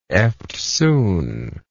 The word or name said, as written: eftsoon